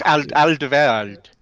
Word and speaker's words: Alderweireld: It's Alderweireld.